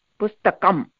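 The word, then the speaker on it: pustakam (पुस्तकम्): And that chinna, that halanta chinna under ma, it's half chinna, it's ma, pustakam, right? It's not the full ma, pustakama, it's not that, pustakam.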